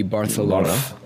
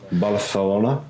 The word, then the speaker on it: Barcelona: If you're using the Catalan pronunciation, it would be Barcelona. – Barcelona.